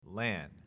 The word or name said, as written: LAN